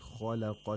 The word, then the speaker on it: خلق: Annallaha alladhi khalaqa as-samawat. Khalaqa. Only the lam has tarqiq. Kha and qaf both have tafkheen. So the mouth opens vertically when pronouncing kha and qaf and horizontally when pronouncing lam.